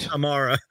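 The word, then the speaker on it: Camara: I need to watch the game a little closer, sort of like how I've been watching, you know, our rookie, Tamari Kamara. Hopefully I said his name right that time. – Nailed it. – Kamara.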